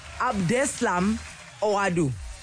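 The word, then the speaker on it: Abdeslam Ouaddou: Abdeslam Oradu. Oadu. Am I saying that right? Abdeslam Oadu. – That's it.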